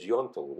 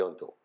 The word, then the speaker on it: Yontl: So I was uh, my Jewish name was Yontel, Y O N T L, mm-hmm. Yontel, and you pronounce everything in Yontel in those words, and. Uh, you pronounce the end, the L. We pronounce the L.